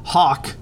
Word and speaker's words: Haack: My final takeaway was Hawk, more like Hack. It's a play on words because Matt Hawk's name is spelled H-A-A-C-K.